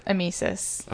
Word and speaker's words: emesis: Another interesting thing that I found is that emesis. Let's use emesis as our word of the week. – How are you saying this word? – Emesis.